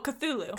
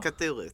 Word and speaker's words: Cthulhu: Oh, Cthulhu. – Cthulhu, thank you.